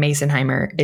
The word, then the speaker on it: Masonheimer: Yeah, so it's Phylicia, but it's spelled P H Y L I C I A, and then my last name Masonheimer is spelled just like it sounds yeah, yeah, yeah.